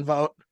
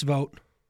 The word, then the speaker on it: Vogt: I mean, you go from established people with Tito Francona to Stephen Vogt. I'm trying to figure out still how to say his name. – It's Vogt. – It is Vogt.